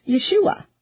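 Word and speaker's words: Yeshua (ישועה): And in Hebrew, of course, it's pronounced Yeshua.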